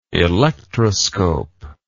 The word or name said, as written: electroscopic